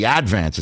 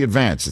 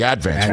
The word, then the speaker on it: Advance: It's just you have to put the accent on the first syllable. It's the Advance. It's not the Advance. It's the Advance.